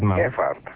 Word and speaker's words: Gephardt: come lo pronunci Gephardt? – Gephardt. – Ah, allora sono andato bene.